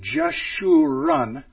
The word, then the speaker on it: Jeshurun: If we would pronounce Israel something like this, Jishrael, then you could see Jeshurun, Jishrael. The J sound, the Sh sound, and the R sound.